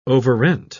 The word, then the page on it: overrent: British English